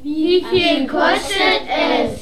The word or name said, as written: wieviel kostet es